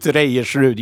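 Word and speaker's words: Reiersrud: I met Knut Rivenstadt. Is that how you say his name? – Yeah, Knut Reyersrud, yeah, from yeah.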